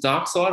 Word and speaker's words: Darkseid: Is it Darkseid? I think that's how you say his name.